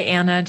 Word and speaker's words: Anne: And I like to go by Anna just because that's what my family always called me, even though it's spelled with an E.